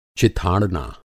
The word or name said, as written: चिथाड़ना